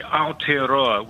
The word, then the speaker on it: Aotearoa: It's Tourism Industry Aotearoa, with Aotearoa being the Maori name for New Zealand.